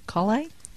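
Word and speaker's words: Collé: But Shine, by a company named Kolle, I think it's pronounced C-O-L-L-E with the little accent, Kolle? – Excellente.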